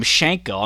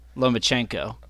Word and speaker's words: Lomachenko: Well, you call it you call him Shanko. I'm pretty sure it's Chenko, Lomachenko. – Okay. Not Same Shank. Thing. Not Shanko.